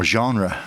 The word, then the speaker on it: genre: genre, as it was. Genre, is it? Yeah, don't try and roll your R's, as it were.